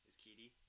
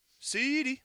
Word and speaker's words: CeeDee: Oh, so like CeeDee Lamb is Keedy? Keedy? Keedy Lamb. – CeeDee. You got to say it like that.